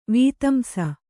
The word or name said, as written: ವೀತಂಸ